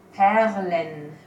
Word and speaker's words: Perlen: And I think it's interesting that you actually pronounce the R there with the tongue. You actually say a perlen. Well, you say it rolled in the back, and I can't do that, so.